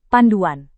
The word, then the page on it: panduan: Indonesian